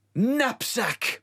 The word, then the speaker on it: knapsack: Knapsack! That's another word for a bag. – That starts with N. – No, it starts with a K. It's a silent K.